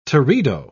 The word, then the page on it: teredo: British English